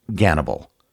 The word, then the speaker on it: Gannibal: The name he chose was Hannibal, or as it's pronounced in Russian, Gannibal.